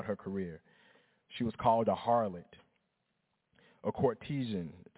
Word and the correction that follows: courtesan: Did I even say that right? I should have read that word before I got up there. Somebody said, I'm supposed to be a journalist, right? A courtesan? Thank you, Mama, a courtesan.